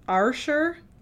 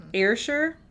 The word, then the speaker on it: Ayrshire: Ayrshire, Ayrshire, A-Y-R-S-H-I-R-E.